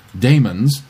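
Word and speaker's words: daemons: I don't know what anachronistic language thing influences these spellings, but Damon's is still pronounced like demons, and orthopedics is still pronounced like orthopedics.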